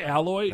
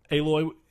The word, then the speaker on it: Aloy: I'm going to say Alloy. – Nope. – Aloy, technically, how you say it, but the reason never mind.